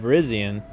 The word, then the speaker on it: Virizion: and Virizion.